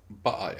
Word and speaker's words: Baal: Ähm, äh, Im Englischen wird bestimmt von Baal gesprochen, oder? Baal, ja. Genau, weil in, ähm, eigentlich im Deutschen ja auch nur in dieser Folge äh, äh, sagen sie noch Baal.